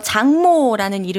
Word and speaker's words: 장모: 장모라는 이름을 가진 분인데요. 감독 데뷔했다는 소식이에요. – 장모, 대단하죠. 장모님이요? – 장모님의 그 장모, 네, 그 발음 네. 맞습니다.